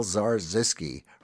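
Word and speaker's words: Zarzyski: Paul Zarzisky rhymes with bar whiskey.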